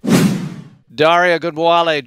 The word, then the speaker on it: Ogunbowale: Dare Ogunbowale joining us on Texans Radio. Did I say that correctly? – Yeah, you said it perfectly.